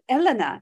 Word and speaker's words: Helena: I pronounced it in the French name, didn't I, David? Helena, I think they pronounced it in Montana, with his wife and their many cats.